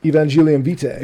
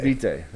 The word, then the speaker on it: Evangelium Vitae: Evangelium Vitae, if I said that correctly. – Evangelium Vitae. – Vitae, there we go. Vitae.